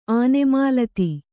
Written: ಆನೆಮಾಲತಿ

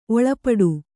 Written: ಒಳ್ಪೊಟ್ಟು

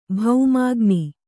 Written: ಭೌಮಾಗ್ನಿ

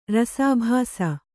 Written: ರಸಾಭಾಸ

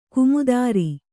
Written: ಗೋಮೂತ್ರ

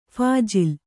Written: ಫಾಜಿಲ್

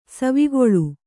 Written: ಸವಿಗೋಲ

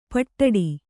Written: ಪಿಡ್ಡ

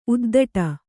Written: ಉದ್ಧಟೆ

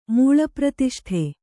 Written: ಮೂಳಪ್ರತಿಷ್ಠೆ

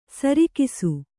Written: ಸರಕ್ಷ

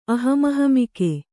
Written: ಅಹಮಹಮಿಕೆ

